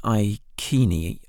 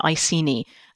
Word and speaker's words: Iceni: Yes, so there's always a bit of debate about whether you have a hard C or a soft C with these things. The Victorians always preferred a soft C, Boudicea, Iceni. But nowadays we tend to think that a hard K is probably a little bit more like how people would have said it in the past.